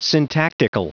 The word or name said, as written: syntactical